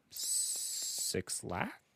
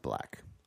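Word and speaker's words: 6lack: The album features guest appearances from Six Lack? I don't know. Six Lack? It's pronounced black.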